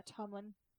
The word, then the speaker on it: Tommen: I believe that's how you say it. Yeah, Tommen.